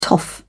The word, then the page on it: toff: British English